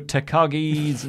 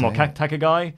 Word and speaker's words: Takagi: Clubber Lang says, and can someone please teach how to pronounce Shingo Takagi's name properly? Kills me every time. Takagi? – Yeah, he says Takagi. – Yeah, it's not right.